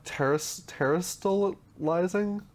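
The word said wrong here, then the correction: Terastallized: Am I saying that correctly? I believe it was terrestrialized. – Terrestrialized was the terrestrialized. – Yeah. There we go. – That sounds better.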